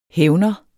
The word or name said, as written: hævner